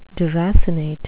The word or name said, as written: deracinate